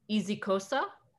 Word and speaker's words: isiXhosa: It's Isixhosa, but so it's I-S-I-X-H-O-S-A, but in their language or the correct pronunciation, the X is a clicking sound.